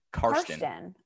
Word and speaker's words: Karsten: So the music aspect of TikTok is actually the thing that I like the most, but I wanted to shout out Carson Belt, which by the way, interesting name, Carson. Never heard that one before. – Can you spell that for me, please? – K-A-R-S-T-E-N. Carsten.